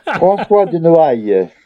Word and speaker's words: François de Noailles: Beyond that, we've got Reverend Thomas, played by Nicholas Pegg, and Francois Denoles, I don't know, played by Barnaby Edwards. – Let me have a look. – My pronunciations on glasses this one are really... – François de Noailles. – Okay, I wasn't too far off.